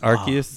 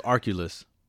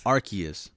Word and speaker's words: Arceus: It rhymes with Barkeus. Go ahead. – The name sounded confusing. I always thought it was Arculus. But what is it? – Arceus.